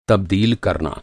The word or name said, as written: तबदील